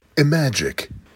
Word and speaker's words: Imagic: Doherty apparently got off the project when he left Mattel to go work at, and here's where helps me out with this pronunciation, Imagic.